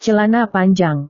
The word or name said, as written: celana panjang